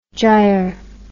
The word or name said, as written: gyr